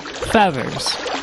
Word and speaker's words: Fyeathers: Feathers. – Can you spell that for me? – F-Y-E-A-T-H-E-R-S.